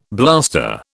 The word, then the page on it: blaster: British English